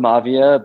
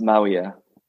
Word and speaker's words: Mavia: So it's spelled Mavia, but it's pronounced Mawia.